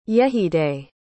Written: yejide

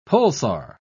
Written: pulsar

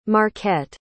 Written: marquette

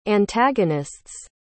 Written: antagonist's

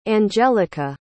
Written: angelika

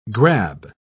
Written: grab

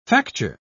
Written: facture

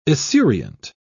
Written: esurient

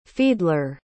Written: fiedler

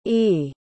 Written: aoidh

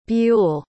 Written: buell

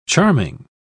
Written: charming

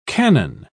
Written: canon

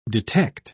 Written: detect

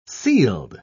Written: sealed

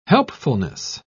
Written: helpfulness

